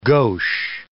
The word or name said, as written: gauche